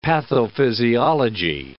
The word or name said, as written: pathophysiology